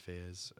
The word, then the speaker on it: Faiyaz: Also, like Wasteland by Brent Faiyaz, if I'm saying that right.